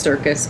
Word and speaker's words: Surkeus: It's actually just Circus, but they spell it S-U-R-K-E-U-S.